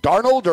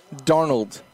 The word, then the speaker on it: Darnold: Darnold or Darno? – Darnold. – Darnold. – Thank you. – Yeah, that's it.